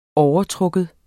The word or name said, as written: overtrukken